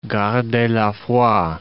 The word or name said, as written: gardez la foi